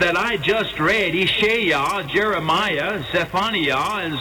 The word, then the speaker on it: Jeremiah: Additionally, such familiar names as Isaiah, Isheyah, Jeremiah, which in Hebrew is pronounced Yeremiah,